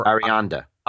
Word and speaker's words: Arianda: Well, I wanted to kind of go back to Venus and Fur and Nina Ariadne. – Or Arianda.